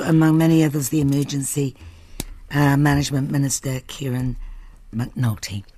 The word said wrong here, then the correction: McNulty: McNulty, excuse me, I always miss out that syllable. I'll have to practice.